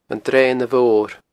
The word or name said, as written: Dreana Mhor